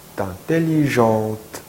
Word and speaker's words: intelligente: Elle est intelligente. Intelligente. 약간 트 발음이 나죠? 그 차이예요.